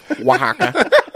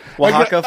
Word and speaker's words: Oaxaca: No, it's Oaxaca. Listen. – Oaxaca. – When you find out something is called Oaxaca, you say Oaxaca a lot.